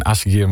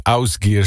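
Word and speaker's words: Ásgeir: Die zei, het is geen Asgeir, maar het is Ausgeir, zo spreek je dat in het IJslands uit.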